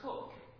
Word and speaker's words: Coke: Back in 1608, Chief Justice Cook, his name is spelled C-O-K-E, and in my ignorance I thought he was Coke, but he's pronounced Cook.